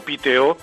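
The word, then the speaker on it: Piteå: Fuimos a un puerto que está situado al fondo del Golfo de Botnia, un puerto que se llama, que se escribe Pitea, pero ellos lo pronuncian algo así como Piteo.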